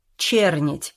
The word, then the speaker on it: Чернить: А при чем здесь эта черная деревня?» «Чернить, говорю же. Ударение на букву «Е».» «Не знаю.